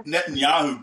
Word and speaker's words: Netanyahu: Netanyahu, Benjamin Netanyahu.